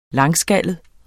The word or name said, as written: langskallet